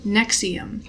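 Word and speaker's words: NXIVM: I didn't know how to pronounce that fucking word, so what is it? – Nexium. – I didn't know either. – Because it's like NV, you know.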